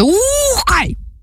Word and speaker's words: Ухай: Ми говоримо про монгольський гурт, який називається «Ухай!». От так він називається, тому що з наголосом, от, власне, на перший склад треба, і довгим звуком «У», і коли вимовляєш це, старайся робити трохи так з живота.